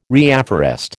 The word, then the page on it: reafforest: American English